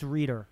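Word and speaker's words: Reiter: I don't know if I'm saying his name right. – I believe it's Reader.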